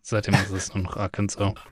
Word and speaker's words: Arkansas: Aber das ist ein S hinten noch dran. Ja, das spricht man anscheinend nicht. – Ah ja, Arkansas.